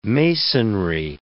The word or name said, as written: masonry